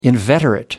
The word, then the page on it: inveterate: American English